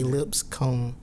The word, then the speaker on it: Lipscomb: And it's spelled like lips on your face, comb in your hair. I don't understand why people have a issue with Lipscomb. I think it's a pretty straightforward name, but it's regular. – I think it's because it's two syllables and people try to squeeze it all in one.